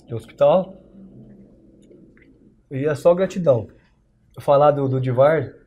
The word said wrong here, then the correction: Edivard: O nome dele eu é pronunciei errado. Edivard.